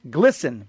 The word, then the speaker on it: GLSEN: The letter noted that Target provides financial support to an organization called GLSEN, pronounced Glisten, mm-hmm.